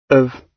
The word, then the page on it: of: British English